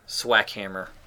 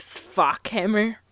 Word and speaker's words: Swackhammer: Is that really how you pronounce it? – Yes. – I don't think there's any other way you could pronounce it. – I was thinking maybe like Sfockhammer, but...